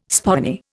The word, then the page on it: spoony: Indian